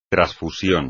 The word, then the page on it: trasfusión: espagnol